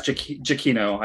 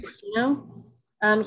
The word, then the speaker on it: Giacchino: Michael, I don't know how to say his last name either, Giacchino? – I think it's Giacchino, I think. – Giacchino. He's got a pretty good record as well.